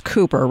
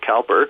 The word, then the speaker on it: Cowper: Now, when we get to William, and it's pronounced Cooper, right? William Cooper. – Well, people pronounce it different ways. My wife, who's from England, pronounced it Cowper. – Okay. – But people do pronounce it both ways.